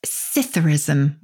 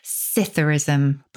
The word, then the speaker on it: psithurism: The second is one I think a lot of people will have heard of, and that's Citherism with a silent P. Citherism. – The P is silent, as in swimming pool. How do you spell this word? – P-S-I-T-H-U-R-I-S-M.